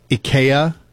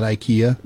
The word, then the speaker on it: IKEA: It's actually supposed to be pronounced IKEA. – Okay. – Apparently that's the more traditional Swedish pronunciation, but we just Americanize it and call it IKEA.